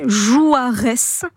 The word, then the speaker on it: Juarez: Et Jouarès, comme ça se prononce.